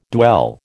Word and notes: dwell: American English

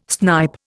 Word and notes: snipe: Indian

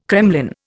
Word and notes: kremlin: Indian

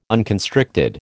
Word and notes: unconstricted: American English

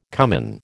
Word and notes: cummin: American English

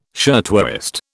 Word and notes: shirtwaist: British English

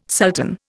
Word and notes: sultan: Indian

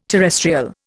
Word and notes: terrestrial: Indian

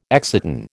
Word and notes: exciton: American English

